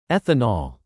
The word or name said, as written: ethanol